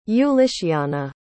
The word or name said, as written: ulyciana